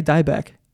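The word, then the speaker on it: Dybec: You say Beck. I say Dybeck.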